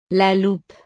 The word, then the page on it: la loupe: French